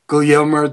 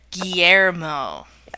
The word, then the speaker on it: Guillermo: Guillermo del Toro. – Yes! – That was one of your more egregious pronunciations. – We're going to ignore it. – We're going to help here. Guillermo.